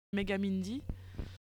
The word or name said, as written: megamindy